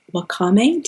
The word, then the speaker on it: Wakame: The first one is Wakame.